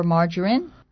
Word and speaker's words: margarine: Butter or margarine. – 버터하고 마가린인데 마가린을 어떻게 발음한다고요? – Margarine. – Margarine 이렇게 하는군요. M-A-R-G-A-R-I-N-E, margarine.